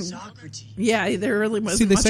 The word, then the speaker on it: Socrates: That Socrates, the guy mm-hmm. that played Socrates. – I'm sorry, it's pronounced Socrates. – Socrates.